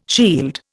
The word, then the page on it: shield: Indian